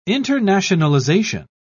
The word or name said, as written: internationalization